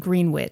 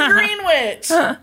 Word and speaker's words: Greenwich: Pronounced Greenwich. – It is not pronounced Greenwich.